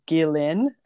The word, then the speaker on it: Ghislaine: It's just a lot, and like the whole like with Ghislaine or whatever her name is, Ghislaine is how you is actually how you say her name, Ghislaine.